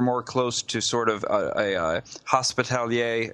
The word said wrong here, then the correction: Hospitaller: Is that how you say it? – Hospitaller. – Hospitaller. Yeah. Thank you.